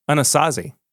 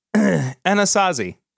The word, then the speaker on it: Anasazi: He can remove memories from the minds of others, cast illusions, and create a portal into the realm of the Anansi. Anasazi. Oh, I'm sorry. I was saying that wrong. Anasazi.